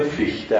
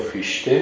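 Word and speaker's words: Fichte: خب ایش پس مشکل ما اینه تو تلفظ اسم آقای فیخته هم همینه. راستش رو بخواهید فیخته است، برای همین ما چون سخته یا فیشته میگیم یا فیخته میگیم بیه چون هیچ دو مزید دوستان.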